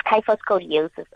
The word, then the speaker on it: kyphoscoliosis: What is kypho or kyphoscoliosis? Am I saying it correctly? – Yes, it's kyphoscoliosis, Asanda.